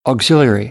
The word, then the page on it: auxiliary: American English